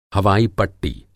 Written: हवाई-पट्टी

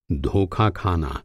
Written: धोखा

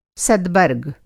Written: सदबर्ग

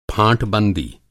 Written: फाँटबंदी

